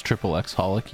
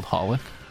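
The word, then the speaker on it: xxxHOLiC: Do you guys know that it's not pronounced triple X holic? You know that the X's are all silent? It's just called holic? – It's not really?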